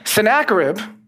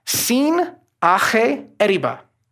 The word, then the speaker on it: Sennacherib: Sennacherib in Assyrian is pronounced Sin Ache Eriba, which means the god Sin, sometimes we pronounce it as Sin in the Bible, it's Sin, it's not quite the same thing.